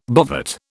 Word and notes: bovate: British English

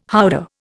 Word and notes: howdah: Indian

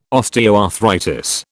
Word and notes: osteoarthritis: British English

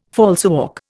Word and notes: falsework: Indian

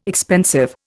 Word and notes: expensive: Indian

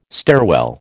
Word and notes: stairwell: American English

